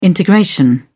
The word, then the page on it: integration: British English